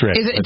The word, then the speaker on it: Reading: Reading, maybe? Reading. It's Reading. – Do you want to pronounce it Reading? – Well, it is Reading, yeah.